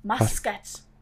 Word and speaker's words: Muscat: Muscat. – Muscat? – Ja, das ähm, schreibt man wie Muscat, also ah. M-U-S-C-A-T.